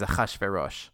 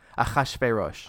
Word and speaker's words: Ahasuerus: All I know is the Hebrew name is Ahasuerus. – Ahasuerus. But no, I said that completely. I butchered that completely.